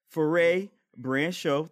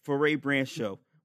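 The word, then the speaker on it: Frères Branchiaux: So, when you go to their website, and I'm going to spell it for you because it's in French, Foray Brand Show, that's FREESBRANCHIAUX. – Shout out to LaJoy Park. – Yeah, Foray Brand Show.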